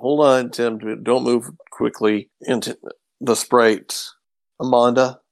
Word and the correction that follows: Amadon: is that how did you say it? – Amadon, thank you.